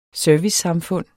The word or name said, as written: servicesamfund